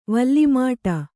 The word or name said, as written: ವಲ್ಲಿಮಾಟ